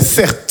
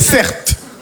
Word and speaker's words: Certe: Je m'appelle Cert. Mon prénom, c'est Certe. Ça s'écrit C-E-R-T-E.